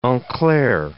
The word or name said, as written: en clair